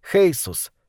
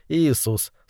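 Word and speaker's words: Jesús: На испанском это имя произносится как Хейсус, тогда как североамериканцы говорят Иисус, словно призывают Создателя.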